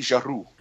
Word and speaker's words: Giroux: And if we can just get the pronunciation right, you got to roll the R. It's Sophie Giroux.